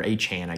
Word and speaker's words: Achane: For Texas A&M, Devin Achane, or Achane, I guess he's saying it's pronounced now, ran for 138 yards, and Connor Weigman threw for over 300.